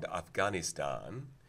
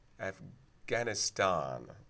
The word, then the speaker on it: Afghanistan: But if you think about country names in the news, Pakistan and Afghanistan, there was a little bit of interesting talk. Obama got knocked for saying Afghanistan. I think he only did the ah on the last syllable, and yet that was considered appeasement.